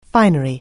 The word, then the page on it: finery: anglais